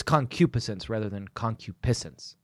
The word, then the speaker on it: concupiscence: A good segue, and I'll allow it, because we're talking about lust and concupiscence. – Yeah. – It's difficult to pronounce. I'm pretty sure that the accent is on the antepenult of that word, but I'm sorry if I'm wrong.